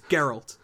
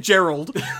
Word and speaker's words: Geralt: It has a hard G. It's Geralt. – Yeah, it's not. – No one has, have, has, ever said Gerald. His name is Geralt.